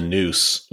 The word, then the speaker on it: Neuse: To be clear, the river that I'm talking about, the Neuse, which runs through Newbern, is spelled N-E-U-S-E. I believe it's a Native American name, actually. – It looks like it would be like a French word pronounced like news, but it like the muse.